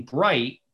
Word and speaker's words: Breite: And then my last name, Breite, but it's spelled B-R-E-I-T-E. – E-I-T-E. B-R-E-I-T-E.